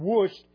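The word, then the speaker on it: Wuest: There's a word study group called WUST, a book, W-E-S-T, I don't know how you pronounce it, but it's WUST.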